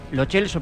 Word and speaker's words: Lo Celso: O se dice en el idioma original, con la pronunciación original, que es Lo Celso porque es de origen italiano, o se castellaniza todo.